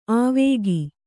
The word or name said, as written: ಆವೇಗಿ